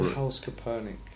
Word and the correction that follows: Kaepernick: Oh, Kaepernick.